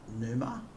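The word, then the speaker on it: Pneuma: Numa. I'd say it's a silent P.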